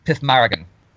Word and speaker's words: Pixmarigan: So I know that P-I-X-M-A-R-I-G-A-N is pronounced Pithmaragan.